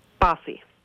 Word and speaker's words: Baasi: Bussy. – Bussy. – Yes. – Will you spell it? – B-A-A-S-I.